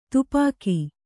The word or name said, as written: ತುಪಾಕಿ